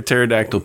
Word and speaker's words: pterodactyl: Why can't you hear a pterodactyl peeing? – Yeah, there we go. I like that one. – Why? Because the pee is silent.